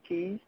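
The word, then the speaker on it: cayes: They C-A-Y-E-S, K-C-A-Y-E-S, but they pronounce it the English way of keys.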